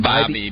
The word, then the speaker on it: Bybee: Is it Bobby? Is that the way you pronounce it? – Bobby. B-Y-B-E-E.